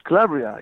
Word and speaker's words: Calabria: But where he came from was Calabria, if I said that right? – Calabria, yeah.